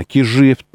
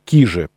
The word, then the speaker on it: Кижи: А, ну, давайте честно, кижи, точнее, правильно произносить, кижи, э, поскольку это финно-угорское слово,